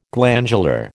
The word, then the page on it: glandular: American English